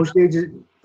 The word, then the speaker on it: Osage: Uh, on Osage, Osage Advisors is correct. – Oh, all right. So, hey, something good happened today. What the heck? Osage Advisors, that's O S A G E.